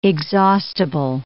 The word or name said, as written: exhaustible